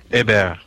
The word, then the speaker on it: Hebert: This is a clip that actually Kyle Hebert, is that how you pronounce his last name? – Hebert. – Ah, well. – Like French.